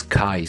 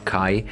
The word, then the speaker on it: Kai: I'm pronouncing it as Kai. Since I'm a German, we pronounce it my name there, Kai. It is K Tours or Kay, Kai Tours.